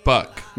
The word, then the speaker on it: Buck: That song was by Book O Owens. – Let's go back. Say it as if it rhymes with duck. – Duck. Buck.